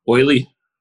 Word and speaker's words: D'Oyly: So you pronounce that. I don't know how. – D apostrophe O Y L Y. – Oily. – D'Oyly. D'Oyly Carte.